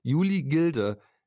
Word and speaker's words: Gilde: Ja, ich habe äh, rausgesucht die Juli Gilde, so wird es ausgesprochen. Ich habe mir die ganze Zeit gedacht, das heißt Gleit, weil ich im englischen Modus war.